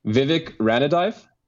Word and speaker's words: Vivek Ranadive: There's, I'm going to butcher this, but Vivek Ranadive.